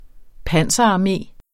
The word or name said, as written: panserarmé